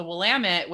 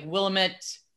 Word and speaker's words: Willamette: And then there's a river, the Willamette, which goes through. But the way that it's spelled, it looks like Willamette.